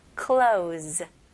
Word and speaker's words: clothes: 일단은 똑같이 클로우로 시작하다가 Z로 마무리합니다. 그렇게 한번 해볼게요. Close. 너무 쉽죠? Close. Z. Z 발음 나와야 돼요.